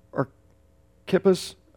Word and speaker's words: Archippus: Archippus, I guess is how it's actually pronounced, is we believe is his son in this.